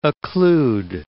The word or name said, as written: occlude